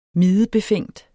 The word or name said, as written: mide